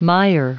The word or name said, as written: mire